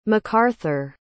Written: macarthur

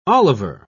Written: Oliver

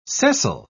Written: sessile

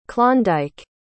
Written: Klondike

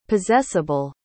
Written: possessable

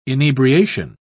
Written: inebriation

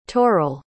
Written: toral

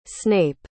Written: snape

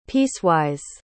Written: piecewise

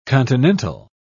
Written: continental

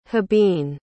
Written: hibine

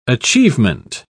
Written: achievement

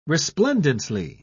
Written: resplendently

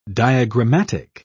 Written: diagrammatic

